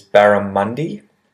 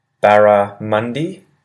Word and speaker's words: barramundi: So the first one is barramundi, and this is a really large fish that lives in rivers. Barramundi, barramundi. And barramundi is spelled B-A-R-R-A-M-U-N-D-I, barramundi.